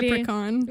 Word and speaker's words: leprechaun: Non, mais tu sais, quand t'as jamais entendu le mot, moi, je suis allée avec la prononciation, c'était un CH. Je me suis dit, c'est ch. – Ouais, mais je pense que c'est ça, en anglais, c'est Leprechaun.